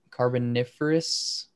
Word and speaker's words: Carboniferous: you were talking about the carboniferous. – Yeah, is that how you say it? Okay. – Mm-hmm. Yeah.